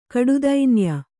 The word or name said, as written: ಕಡುದೈನ್ಯ